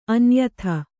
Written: ಅನ್ಯಥಾ

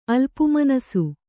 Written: ಅಲ್ಪುಮನಸು

